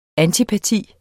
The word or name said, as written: antipati